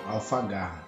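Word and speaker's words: Alfaguara: Não sei se é essa pronúncia correta. Alfa, Alfagara? É isso? Alfagara. Alfagara. É.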